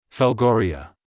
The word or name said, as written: Velgoria